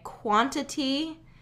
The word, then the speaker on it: quantity: Quantity has a pronunciation that includes a tap T or a flapped T, however you want to call it. Quantity. Okay, if I'm going slowly with it, quantity.